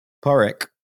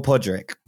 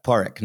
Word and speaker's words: Pádraig: Porrick or Pádraig, but Porrick normally. – Porrick.